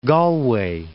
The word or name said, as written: Galway